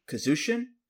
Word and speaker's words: Kazsin: Bishamon's theme is by Kazushin, I think it's how you say it, K-A-Z-S-I-N.